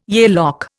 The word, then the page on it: Yale lock: Indian